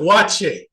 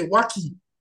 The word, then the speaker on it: waakye: Like, there's one thing, beans and rice here in Ghana, they call it wache, right? – Yeah. – But it's spelled W-A-A-K-Y-E. Now, you know, as an African American, I look at that, I say Waki, K-Y-E, Waki, right? Waki. Or they have this name Boache, B-O-A-K-Y-E. We look at that, it looks like Boaki, right? But they, in the middle, they pronounce that K as a C-H.